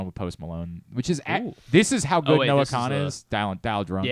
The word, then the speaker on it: Kahan: Noah Kahan? – Noah Kahan. Yeah. I think it's just Kahn is how he pronounces it.